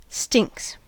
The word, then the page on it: stinks: American English